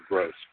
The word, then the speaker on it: Grohs: His last name is GROHS, but it sounds like the word gross.